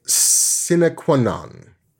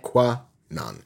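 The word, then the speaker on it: sine qua non: Sinequanon. Because it's not like it's Latin. So you're not like trying to drop any syllables or whatever. So it's not like Sinequanon. No, no. Every letter in there belongs.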